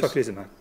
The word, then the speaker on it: Tocilizumab: Tocilizumab.